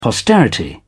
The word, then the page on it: posterity: British English